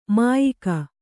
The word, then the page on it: ಮಾಯಕ: kannada